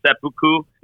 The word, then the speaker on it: seppuku: It's seppuku.